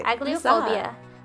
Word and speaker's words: Agliophobia: Dami is how do you even pronounce this word? Agliophobia. – You said it right. – Agliophobia, which is the persistent, unwarranted, and often irrational fear of pain.